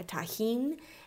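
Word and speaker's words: Tajín: And if you don't have access to Trader Joe's, there is the seasoning that is spelled T-A-J-I-N, a tahine.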